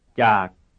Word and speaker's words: จาก: จาก. จาก. จาก. – What is the pitch contour? Right, it's low falling.